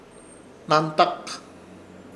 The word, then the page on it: Hnantak: Ishir; Chamacoco